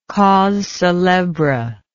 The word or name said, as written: cause celebre